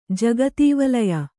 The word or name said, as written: ಜಗದ್ವಲಯ